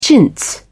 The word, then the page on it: chintz: British English